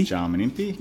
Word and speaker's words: Jarman Impey: I guess like your third son, Jarman Impey. – Jarman Impey.